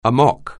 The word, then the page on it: amok: anglais